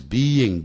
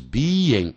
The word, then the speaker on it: being: 如果你念 being,B-E-I-N-G, 要念 being, 啊，说 the job is being done, 这工作正在被做。 The letter is being written, B-E-I-N-G, 念成 being, B-E-E-N, 念成 Ben。